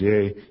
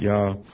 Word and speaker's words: Yeshua: demonstrates the vocalization of the first syllable as Yeh and not Yah.